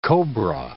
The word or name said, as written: cobra